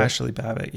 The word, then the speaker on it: Babbitt: Ashley Babbitt, yeah.